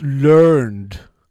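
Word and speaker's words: learnèd: And it's pronounced learned.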